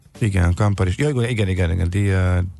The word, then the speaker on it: Diageo: A helyes kiejtés Diageo. A név ugyanis a dia, mint nap, és a geo, geo, mint föld szavak összetétele, ezzel utalva a globális mi voltára. Köszi szépen. Szóval, Diageo.